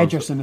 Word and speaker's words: Ederson: Edison dropped a few balls. – Ederson is how you pronounce it.